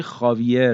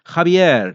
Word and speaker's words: Javier: ولی این اسم توی تلفظ رسمی اسپانیایی هاویه خونده میشه و همونطور که میدونید سیستم تلفظ اسامی توی اسپانیایی از یک شهر به شهر دیگه و حتی از یک منطقه به منطقه دیگه فرق میکنه و همین اسم توی تلفظ مادریدی که شهر زادگاه ماریاس هم هست خابیر تلفظ میشه، حالا اگه من درست خونده باشمش، که توی این دوتا کتاب خانم ملک مرزبان به درستی به همین شکل نوشته شده.